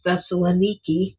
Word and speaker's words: Thessaloniki: And so Thessalonica, today is pronounced Thessaloniki, was the largest city in Macedonia at the time of the Apostle Paul's visit in 49 AD.